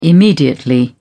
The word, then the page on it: immediately: British English